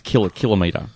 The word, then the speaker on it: kilometre: Well, it's not the right way to pronounce the word. This is That was my point. – It's kilometre. – It's kilometre. – Kilometre. – Yes. – Thank you. – Kilogram. – You don't say millimetre.